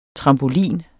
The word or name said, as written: trampolin